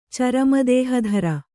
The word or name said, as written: ಚರಮದೇಹಧರ